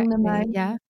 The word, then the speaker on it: Ong Namo: Is this something that is Ong Namo? Have I pronounced that correctly? – Ong Namo.